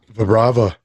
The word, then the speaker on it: Vibrava: It's Vibrava. – Vibrava.